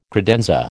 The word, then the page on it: credenza: American English